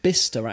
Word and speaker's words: Bicester: Oh, it's called Bicester, actually.